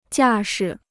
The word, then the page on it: 架势: jià shi